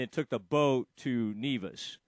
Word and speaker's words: Nevis: How do you say that? Is it Nevis or Nevis? – Nevis. – Uh-huh, Nevis. – Nevis. Okay.